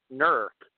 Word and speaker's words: Newark: and actually it's pronounced Newark.